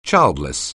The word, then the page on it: childless: English